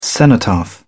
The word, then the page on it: cenotaph: British English